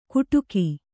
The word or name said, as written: ಗುಟುಕೀ